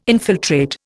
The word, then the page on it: infiltrate: Indian